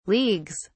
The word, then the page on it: leagues: British English